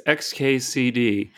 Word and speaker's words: xkcd: You've got it right there. It's XKCD. I actually spent a lot of time trying to pick out four letters that would be clear that they didn't have an obvious pronunciation, so that people would not try to read it as a word. I wanted it to look as much like a string of random letters as possible.